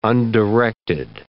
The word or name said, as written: undirected